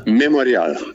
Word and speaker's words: Memorial: Wie wird das eigentlich russisch ausgesprochen? Memorial? – Memorial. Memorial, das ist ja ein internationales Wort.